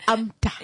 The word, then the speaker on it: AMTA: AMTA in short, not NAMTA, not ANTA, but it's AMTA. – Yeah. – AMTA. Yes. Cause we've heard this. I think we should also run a campaign on that to say, please pronounce AMTA, not ANTA or NAMTA.